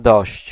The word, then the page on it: dość: [dɔɕt͡ɕ]